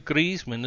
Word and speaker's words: Griezmann: And they had to offload Griezmann as well.